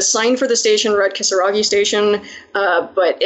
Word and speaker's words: Kisaragi: But apparently, although the name of the station is pronounced Kisaragi, it was written with the kanji for demon.